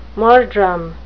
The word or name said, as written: murdrum